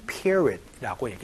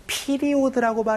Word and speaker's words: period: 그런 것들을 바로 probation period라고 얘기를 합니다. 피리오드라고 발음하지 않고요. period 이렇게 발음하니까 요거 주의해 주시면서 수습 기간이 이제 끝났어요.